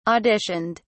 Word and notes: auditioned: British English

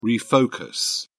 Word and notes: refocus: British English

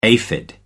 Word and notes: aphid: American English

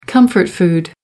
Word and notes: comfort food: American English

